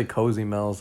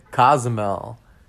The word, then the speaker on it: Cozumel: I mean, you could say Cozy Mel's. That's technically what it says. But I'm pretty sure it's Cozumel.